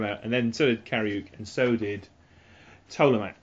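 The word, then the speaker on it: Kariooq: Kariuk. – That's K-A-R-I-O-O-Q for the listener.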